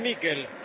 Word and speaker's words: Miquel: ¿Cómo le decimos? ¿Michael o Miquel? Nicolás Álvarez, usted que es especialista en nombre. Se escribe Miquel. ¿Cómo le digo? – Decile Miquel.